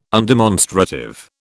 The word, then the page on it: undemonstrative: British English